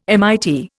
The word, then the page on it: MIT: Indian